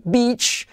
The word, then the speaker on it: beach: Por lo tanto, playa se escribe con E y se dice con I, beach.